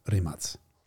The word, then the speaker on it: Rimac: And if you would like to be an expert in automotive pronunciation, you will call it Rimac. – Yes. – Worst case, Rimac. But not Rimac. Anyway, the Rimac Nevera, which was named, do you know how it was named?